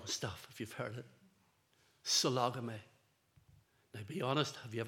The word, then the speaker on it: sologamy: It's spelt as it sounds, S-O-L-O-G-A-M-Y, sologamy.